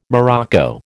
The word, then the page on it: morocco: American English